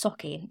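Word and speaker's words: Sauchie: But I think it's Socky.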